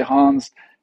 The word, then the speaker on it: Hans: Most people say Hans, and last name is usually Boersma.